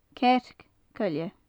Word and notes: cearc coille: gaelic

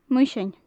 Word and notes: muisean: gaelic

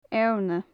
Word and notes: eorna: gaelic

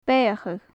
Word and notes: beathachadh: gaelic

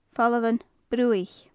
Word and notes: famhalan bruaiche: gaelic

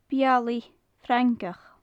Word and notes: bealaidh-frangach: gaelic

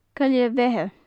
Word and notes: coille bheithe: gaelic

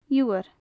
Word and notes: iubhar: gaelic

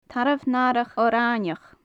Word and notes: tarbh natharach orfhainneach: gaelic

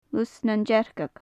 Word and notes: lus nan dearcag: gaelic

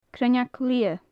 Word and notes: cruinneag liath: gaelic

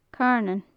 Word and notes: carnan: gaelic

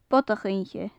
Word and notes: bodach-oidhche: gaelic

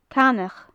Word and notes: canach: gaelic

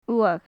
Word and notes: uath: gaelic

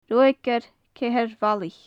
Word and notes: ruagaire ceithir-bhallach: gaelic

